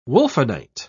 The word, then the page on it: wulfenite: British English